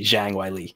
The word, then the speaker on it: Zhang Weili: The Chinese pronunciation would be Zhang Weili.